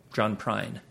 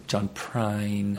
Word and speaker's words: John Prine: Like, if we went open for John Prine, three shows this John Bryan? – John Prine. – John Bryan, yeah. – Prine. – You're saying it wrong, but yeah.